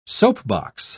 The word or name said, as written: soapbox